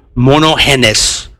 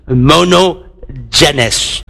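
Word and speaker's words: monogenes (μονογενής): Now, when you isolate that word, the Greek word is mono, let me pronounce it in Greek, monogenes, or transliteration in English, monogenes.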